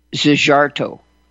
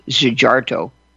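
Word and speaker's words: Szijjártó: Hungary's foreign minister, and forgive me for my effort at pronunciation, Zsigarto, S-Z-I-J-J-A-R-T-O, Zsigarto, said, "We stand with President Trump.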